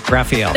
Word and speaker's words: Raphael: And the Ninja Turtle is pronounced Raphael. – That's right.